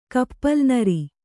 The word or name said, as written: ಕಪ್ಪಲುನರಿ